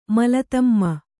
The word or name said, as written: ಮಲತಮ್ಮ